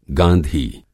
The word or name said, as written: गाँधी